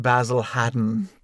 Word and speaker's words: Basil Hayden: And I've learned that Basil Hayden is pronounced Basil Haddon. – That is correct.